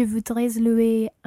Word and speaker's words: voudrais: Je voudrais louer une voiture. – Okay, in this one, you wouldn't pronounce the S of je voudrais because it's not followed by a vowel.